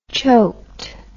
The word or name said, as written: choked